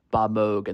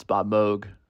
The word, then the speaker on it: Moog: On today's episode, you'll be hearing another interview that Sam did, this time with Albert Glinsky, who wrote the book Switched On, Bob Moog and the Synthesizer Revolution. And that's right, it's Bob Moog, not Bob Moog.